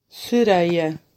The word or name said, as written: Sereia